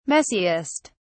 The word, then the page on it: messiest: British English